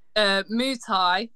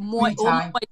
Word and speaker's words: Muay Thai: Muay Thai or Muay Thai. That's basically translate as Thai boxing.